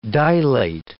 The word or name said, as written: dilate